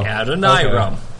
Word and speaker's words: Adoniram: It says, "Pronounce it to rhyme with Uncle Hiram." – Oh, there you go. – Adoniram.